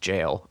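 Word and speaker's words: gaol: There's something called jail fever, spelled G G A O L, which is pronounced jail, apparently, where people get these r- just ridiculous forms of typhus and typhoid because they're living in this filth.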